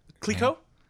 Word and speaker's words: Clicquot: Can you pronounce the second part of that? – Clicquot.